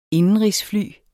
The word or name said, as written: indenrigsfly